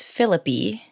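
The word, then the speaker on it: Phillippe: And also there are some like really great witty lines that draw some laughs, particularly from Ryan Philippe. I really liked this character. – I believe it's pronounced Philippi.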